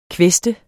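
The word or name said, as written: kvæste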